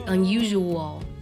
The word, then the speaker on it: unusual: 这句话当中的 unusual 是最难读的，特别是呢，它的 s 发的是 r 的音，不少人呢发成了 r 的音，读成了 usually unusual，这是错的。它实际的发音是 unusual， usual， usual， r 的音。